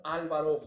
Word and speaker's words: Álvaro: Yo me llamo Álvaro Gordoa Fernández. Álvaro es una palabra esdrújula, se tendría que tildar, tener acento en la A.